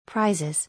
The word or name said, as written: Prizes